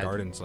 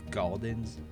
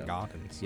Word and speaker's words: Gaudens: Why'd you say gardens like you're from New Jersey? – Gaudens? Well, it is spelled G-A-U-D-E-N. – Oh, okay, so. Gaudens. – Yeah, so it's not gardens. Yeah, St. Gaudens. Gaudens, A. That's not New Jersey.